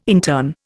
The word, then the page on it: intern: Indian